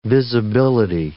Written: visibility

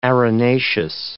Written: arenaceous

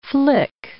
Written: flick

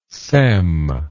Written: sem

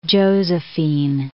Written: Josephine

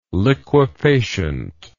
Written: liquefacient

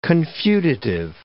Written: confutative

